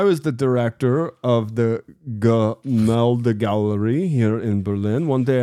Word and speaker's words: Gemälde: It's pronounced Gemälde Gallery.